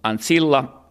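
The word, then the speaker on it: ancilla: Cum ankillaa har antagligen varit cum ansilla, ansilla, ansilla med korta på slutet. Men framförallt det här C har inte uttalats som ett K.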